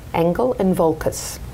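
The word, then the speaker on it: Engel & Völkers: Engel and Volkers, Ocala.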